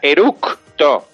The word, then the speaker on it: eructo: No se dice eruto, ¿eh? – No, No. – Eruto. – Se dice con C de casa, eructo. – No se puede decir eructo. – Eructa, no se dice ni erutar. Sin ni eruptar con P de Pamplona. O sea, eruptar con P de Pamplona en medio entre la O y la P está mal dicho. Y eruto así, entre, o sea, E-R-U-T-O, eruto tampoco se puede decir porque está mal dicho.